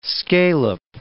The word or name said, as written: scale-up